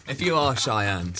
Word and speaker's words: Cheyenne: let me spell that for you. It's C-H-E-Y-E-N-N-E. Cheyenne.